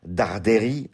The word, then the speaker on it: Darderi: Direction l'Open de Suède, Bastat, Nuno Borges, 52e mondial contre Luciano, si vous le prononcez à l'italienne, Darderi.